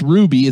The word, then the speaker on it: RWBY: So RWBY, pronounced Ruby, is an American anime style animated web series.